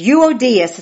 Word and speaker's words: Euodias: Euodias is how you pronounce that.